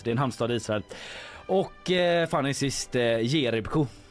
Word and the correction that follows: Jerebko: är ju jag som uttalar Jerebko fel och det är ju en basketspelare.